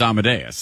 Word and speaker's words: Amadeus: Yeah, because you're just reading it, and you don't know how to say it, because if you've ever heard it, you know it's Amadeus. Like, it just is.